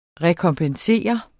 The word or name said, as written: rekompensere